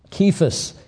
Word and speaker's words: Cephas: We often pronounce it Cephas. Probably a little better with a K sound on that C. Cephas.